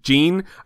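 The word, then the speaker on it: Ging: Oh my God, I just fast-forwarded to hear what the narrator says, and I heard Aunt Mito pronounce Gon's father Jing, Jean. I remember that. It's basically Jean.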